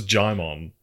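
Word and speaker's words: Djimon: Digimon Honsu, Regina Hall, Nicki Minaj. – Digimon. – Is that not how you say it? – No, it's Jaimon.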